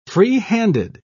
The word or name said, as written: freehanded